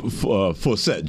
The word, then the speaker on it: Forsett: Forsyth, right? – Forsett, Justin Forsett. – Right. – Rhymes with Dorsett, you know, right?